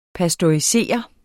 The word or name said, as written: pasteurisere